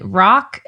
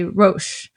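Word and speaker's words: Roch: His first name is, in English, you pronounce it Rock, and then in French, you say Roche. So I'm going to pronounce it French because he's from Quebec, okay? – Okay.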